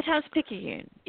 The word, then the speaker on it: Times-Picayune: The Times-Picayune, yes.